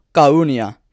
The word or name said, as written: Charunia